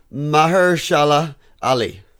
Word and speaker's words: Mahershala Ali: Mahershala Ali. – Yeah, Ali. – Not that hard. – If Dave... – And I could barely read. – Mahershala Ali.